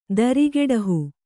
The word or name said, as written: ದರಿಗೆಡಹು